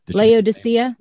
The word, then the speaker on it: Laodicea: And it's that because the Church of Lucia, which in Revelations, I think, or is that, am I saying that right? Is it Lucia? – Revelation. – The Laodicea. – Laodicea. Thank you. I knew I was missing some.